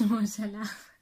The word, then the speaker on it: Mo Salah: Teach you how to pronounce Mo Salah.